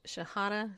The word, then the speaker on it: Shahada: the Shahada, is that right? – Yeah. – Prayer. I said it right, people.